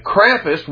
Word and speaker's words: Krampus: It's K-R-A-M-P-U-S. I pronounce it Krampus.